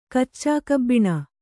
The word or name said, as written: ಕಚ್ಚಾಕಬ್ಬಿಣ